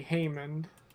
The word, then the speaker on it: Haymond: Now, how do you spell that for everyone? – H-A-Y-M-O-N-D, Haman.